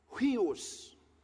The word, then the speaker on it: huios: The word he uses is huios. Now, huios, H-U-I-O-S. It's pronounced H-W-E-E-O-S.